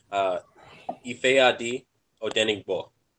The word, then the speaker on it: Ifeadi Odenigbo: Ifeadi Odenigbo. That's how you pronounce it. – So Ifeadi Odenigbo. – Okay. – There's that G, that GB creates a different sound in Yoruba or just in Nigerian language.